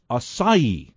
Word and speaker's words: acai: The big one is acai. That's the way you pronounce A-C-A-I.